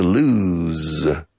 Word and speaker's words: lose: Now, perder is to lose. O simple, lose, lose. And the S is pronounced like an English Z.